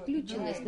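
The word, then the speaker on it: включённость: Все-таки вот включенность, она очень хорошо. – Включенность, наверное. – Включенность, да. – Ударение.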